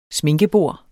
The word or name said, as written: sminkebord